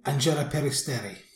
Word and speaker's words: Angela Peristeri: So Albania, Karma, and uh, oh, I realise I can't pronounce their name. Angela Peristeri. Angela Peristeri. There you go.